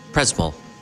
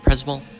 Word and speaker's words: Przemyśl: It's Przemyśl. Przemyśl.